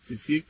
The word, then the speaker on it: Tewfiq: Tufik is spelled T-E-W-F-I-Q, last name G-E-O-R-G-I-O-U-S.